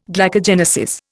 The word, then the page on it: glycogenesis: Indian